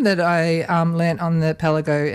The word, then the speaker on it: Kpalango: So, yeah, so it's spelled actually K-P-A-L-A-N-G-O, which you might think sounds like Kapanlago, right? – Yeah, it's like Pialago down in, in Cambros. – But being a Ghanaian word in the language of Ga, which is the language it's in, it's pronounced Pelago.